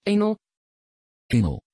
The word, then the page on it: énaël: Dutch